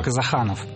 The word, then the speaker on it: Казаханов: Я правильно ударение поставил? – Дело в том, что у меня вообще другая фамилия. – Так. – Я Эльдар Казаханов.